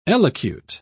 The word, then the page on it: elocute: British English